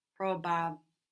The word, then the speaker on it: probabile: Take it slowly, syllable by syllable. – Probabile. – Probabile. – Probabile. – So it's just like the English. If you think of the English probable, and then you make each of those vowels more Italian sounding, so probabil. – And then you pronounce the E that we don't pronounce in English.